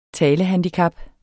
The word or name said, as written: talehandicap